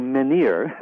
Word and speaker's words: menhir: So menhir, the French pronunciation, yes.